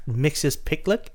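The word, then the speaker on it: Mxyzptlk: think, and it's Mixius Picklick.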